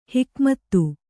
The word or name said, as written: ಹುಕುಮತ್ತು